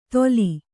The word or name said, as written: ದೋಲಿ